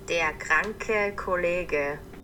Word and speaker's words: Der kranke Kollege: Now, this game is called Escape Room, and here comes my German, Der Kranke der Kollege. – Der Kranke Kollege. – Thanks from Geek Herring for that correct pronunciation, which translates into the sick colleague.